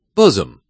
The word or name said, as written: bosom